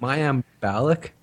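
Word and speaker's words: Mayim Bialik: Mayim Balik.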